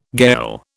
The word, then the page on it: gayal: British English